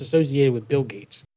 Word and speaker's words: Gaetz: It is Gates. – Gates. I told you. No, I thought it was Getz. No, it's Getz. Isn't it? – No, Gates. It's Gates. Like Bill Gates.